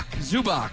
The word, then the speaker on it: Zubac: Is it Zubac? Am I saying that right? – Zubac.